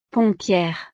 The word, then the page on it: pompiere: frances